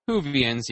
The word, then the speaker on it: Whovians: I was walking with a bunch of other Whovians, hoping to catch a glimpse. They were on break, on a shooting break when I got there. – Is that what Doctor Who fans are called? – Whovians, yeah. – Whovians? – Whovians. – Whovians, and it's spelled Who. – V-I-A-N-S.